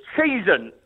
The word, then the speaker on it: Cheshunt: It's Cheshunt.